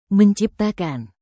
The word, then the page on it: menciptakan: Indonesian